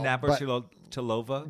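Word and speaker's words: Navrátilová: Navratilova? – Yeah.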